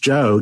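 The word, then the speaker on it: Geo: It's spelled G-O and pronounced Joe Gamow.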